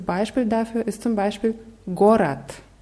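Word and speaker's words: Gorat (город): Wird mit zwei O's geschrieben. Gorod. Die Betonung ist auf dem ersten, also sprechen wir im ersten Fall O und im zweiten Mal wieder dieses reduziertes A. Gorat. – Gorat.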